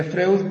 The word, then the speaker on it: Freud: Se escribe Freud, pero se pronuncia Freud, el austriaco, ¿verdad?